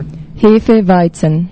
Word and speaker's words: Hefeweizen: Yeah, there's terrible ways to pronounce Hefeweizen. What's the authentic way for? – Hefeweizen. – Hefeweizen. – No, that's easy.